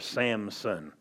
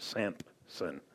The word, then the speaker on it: Samson: Or Samson, not Sampson.